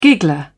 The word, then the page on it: giggler: British English